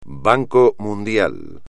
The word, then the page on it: Banco Mundial: espagnol